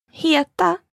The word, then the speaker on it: heta: Heta belongs to a group of verbs with simple pronunciations. – All you need to know for now is that the stress is on the first syllable.